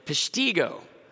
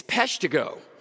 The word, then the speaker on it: Peshtigo: And I said, Peshtigo. It's Peshtigo. Don't mess with Wisconsin.